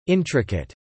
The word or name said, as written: intricate